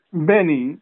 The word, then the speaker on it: Benny: Benny, that's Bet Nunius.